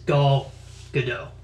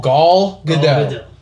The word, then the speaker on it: Gal Gadot: And he said the pronunciation is Gal Gadot. – Gadot. Okay. Gal Gadot. Gal Gadot. – Gal Gadot. – Ellen, Gal Gadot. Point blank asked, how do you say her name? – Gal Gadot. – Ben Affleck said Gal Gadot.